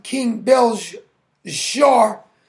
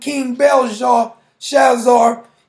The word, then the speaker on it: Belshazzar: I think it's pronounced King Belshazzar.